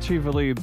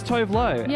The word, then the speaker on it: Tuvalu: Apparently it is pronounced Tuvalu, but for years people would just see it and go, oh, it's Tovlo.